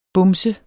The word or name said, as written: bumset